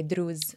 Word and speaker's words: Druze: Maybe we should pronounce it the Arab way, Druze.